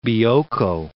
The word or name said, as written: Bioko